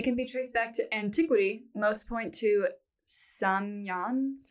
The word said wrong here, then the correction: Samhain: I saw that it's pronounced Samhain. – Oh, Samhain, you're right. – Yeah, right. – I don't know why they have an M in there, but I don't know about...